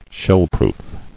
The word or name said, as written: Shellproof